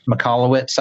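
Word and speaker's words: Michalowicz: Mikalowicz, I believe is how you say it.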